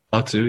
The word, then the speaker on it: Lao Tzu: Is that how you pronounce it? Lao Tzu? – Lao Tzu, yeah.